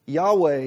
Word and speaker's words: Yahweh: Yahweh is the pronunciation that has kind of been adopted.